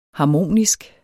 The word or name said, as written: harmonisk